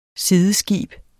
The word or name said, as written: sideskib